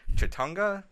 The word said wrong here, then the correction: Chattanooga: Film Festival? What's the other one you're going to? I always say it wrong. – Chattanooga? – Yeah, that's Chattanooga.